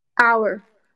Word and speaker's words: our: Or Our. – Our, our, our. – Our. – É, escreve assim, né? O-U-R, O-U-R. Mas se fala our.